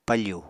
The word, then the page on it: pafù: Apatani